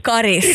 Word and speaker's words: Karis: Karis, som det heter. – Karis. Karis säger jag det på mitt uttal.